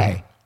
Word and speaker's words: Jorge: Is it George? Or is that always pronounced Jorge? – Jorge. – Yes. – For sure.